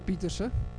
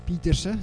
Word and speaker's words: Pieterse: For those of you that don't know me, my name is Reinald Pieterse. Pieterse, it sounds like an English pronunciation. I'm actually Afrikaans.